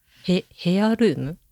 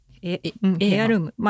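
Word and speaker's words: heirloom: ヘアルーム？ – エアルーム。まあエアルームっていう発音になるんだと思うんだけど、うん、 H-E-I-R? ヘアルーム？ – へぇ。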